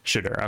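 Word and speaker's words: Shedeur: Shadur, okay.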